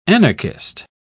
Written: anarchist

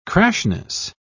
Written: crassness

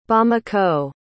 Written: bamako

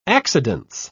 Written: accidence

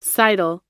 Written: seidel

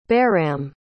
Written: baram

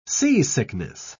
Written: seasickness

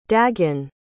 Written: daegan

